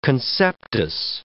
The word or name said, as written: conceptus